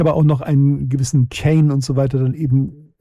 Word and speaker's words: Keane: Von der Frau Monika wird dann nicht Keen ausgesprochen. Nein, wird Kane ausgesprochen.